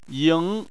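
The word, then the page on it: ying: tone 2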